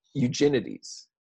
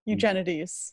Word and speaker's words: Eugenides: I know we just went over how to pronounce this, like literally 2 seconds before the episode started, but Jeffrey Eugenides. – Eugenides. – Eugenides.